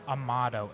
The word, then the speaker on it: Amato: Well, folks, we're at Divine Barrel, and we have the absolute pleasure to have on our episode James Amato, and I hope I said that correctly.